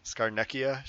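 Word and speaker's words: Scarnecchia: Skarnecchia should have been the MVP of the game.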